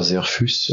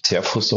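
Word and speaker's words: Zehrfuss: Und das ist ein Museum, was in den 70ern gebaut worden ist von Peter Serfus, also würde sich Zerfus auf Deutsch aussprechen, der ein Museum in einen Hügel gebaut hat mit einer Treppe von einer Schönheit, die ich selten gesehen habe, ein unglaubliches Erlebnis.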